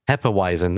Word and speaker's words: Hefeweizen: How do you say that? – Hefeweizen. – That's right.